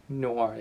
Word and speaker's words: Noir: Correct me if I'm wrong with the pronunciation, but Noir? – Noir, yeah.